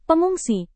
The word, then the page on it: pengungsi: Indonesian